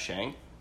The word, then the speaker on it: Shang-Chi: It is Shang, not Shang. – Ah, good to know. – Listened to an interview with one of the cast members, and I thought it was Shang.